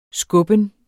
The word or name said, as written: skubben